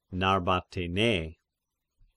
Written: Narbatena

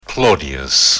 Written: Claudius

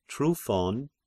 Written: Tryphon

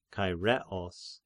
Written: Chaireos

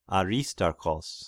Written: Aristarchos

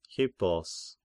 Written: Hippos

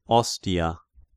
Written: Ostia

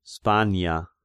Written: Spania

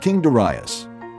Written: Darius

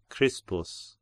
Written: Crispus

